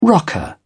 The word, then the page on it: rocker: British English